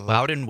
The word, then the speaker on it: Loudon: I don't know how you say L-O-U-D-O-N. Loudon? Loudon? Yeah. Loudon Wainwright III.